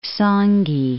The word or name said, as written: Sangi Islands